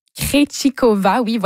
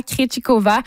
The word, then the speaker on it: Krejčíková: Et au troisième tour du double féminin, la Canadienne Gabriela Dabrowski et la Tchèque Barbora Krejčíková, Barbora Krejčíková ont perdu aux mains des Tchèques Linda Noskova et Marie Buskova.